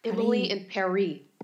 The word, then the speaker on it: Emily in Paris: Okay, but listen, I read yesterday, Netflix tweeted that Emily in Paris is supposed to be pronounced French, so that Emily and Paris rhyme. Emily in Paris.